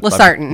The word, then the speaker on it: Losartan: Losartan. – Losartan, yep.